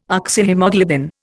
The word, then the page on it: Oxyhaemoglobin: Indian